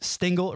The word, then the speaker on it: Stingl: So it's Jeremiah Stingl, and Stingl spelled with an L on the end, no E. So just Stingl, it rhymes with jingle.